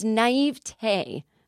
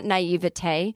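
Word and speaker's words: naïveté: I think that is how you pronounce it, and I really kind of get off on myself that I say it correctly. It's not naivete. Maybe it is, but I like to say it with one less syllable because it makes me feel international.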